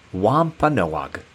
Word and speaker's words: Wampanoag: Their name is pronounced Wampanoag, which means people of the dawn and people of the first light.